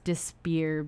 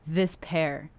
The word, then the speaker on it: Despair: And it's people think it's Despair because it's like that's how it's spelled, but it's actually this pair, but it's like this, like look at this.